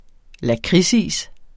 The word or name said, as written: lakridsis